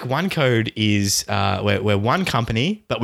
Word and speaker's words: OneQode: What is OneCode? How is it spelt? – It's spelt O-N-E-Q-O-D-E. Nice. So OneCode.